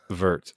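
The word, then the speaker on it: Würth: Vert.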